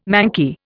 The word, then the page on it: manky: Indian